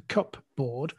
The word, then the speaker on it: cupboard: So it was the cupboard. But now, strangely, we've kept the P in there, but we pronounce it cupboard.